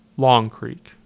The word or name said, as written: Long Creek